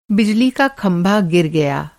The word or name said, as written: खंभा